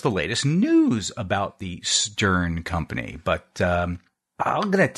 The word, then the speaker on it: Steorn: Company based out of Dublin, Ireland, called Stern. That's spelled S-T-E-O-R-N. The O is silent, at least in our language.